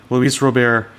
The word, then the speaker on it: Robert: It's unexpected Robert. French. Yep. Unexpected French. Luis Robert.